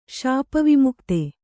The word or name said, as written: ಶಾಪವಿಮುಕ್ತ